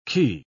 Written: key

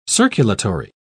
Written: circulatory

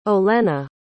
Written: Olena

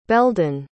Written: beldan

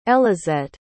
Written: elizette

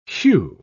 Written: whew

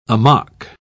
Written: amok

